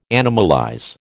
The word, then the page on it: animalize: American English